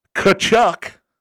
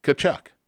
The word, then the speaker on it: Tkachuk: Keith Tkachuk.